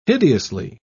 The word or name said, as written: hideously